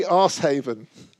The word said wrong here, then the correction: Arshavin: I think it's pronounced Arshavin.